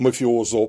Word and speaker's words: мафиозо: Мафиозо, по-моему, да, правильно произносить? – Если один. – Да, один мафиозо. – Да, много мафиози. Да.